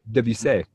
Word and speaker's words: Debussy: Debussy.